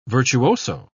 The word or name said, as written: virtuoso